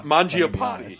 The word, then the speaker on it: Mangiapane: And that is Magipie, I believe is how you say his name. Magic? He sounds like a Pokemon. – Mangiapane. You're not even in the room, like in the fucking stratosphere with that.